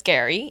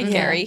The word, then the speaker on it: Gerry: The first Golden Bachelor, his name is Gary, even though it's spelled Jerry. – Right. I noticed that too. I was like, oh. – G-E-R-R-Y, Gary.